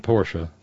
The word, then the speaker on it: Porsche: It really is a two syllable word. Ferdinand Porsche, Ferry Porsche, was a German automobile designer.